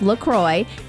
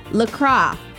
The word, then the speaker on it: LaCroix: So officially it is pronounced LaCroix. It rhymes with enjoy, not LaCroix, like the French might say it. LaCroix.